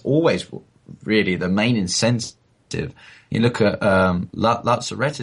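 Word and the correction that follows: Latts Razzi: is that how you pronounce the name? – Lazarazi, that's it.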